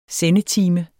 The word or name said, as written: sendetime